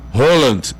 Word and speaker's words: Haaland: Nós chamamos aqui Haaland, mas sabemos que o nome correto dele é como você pronuncia, Haaland, é mais fechado, né?